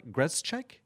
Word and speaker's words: Grzelcyk: And you pronounce it, Grzelcyk? – Grzelcyk. – Grzelcyk, thank you.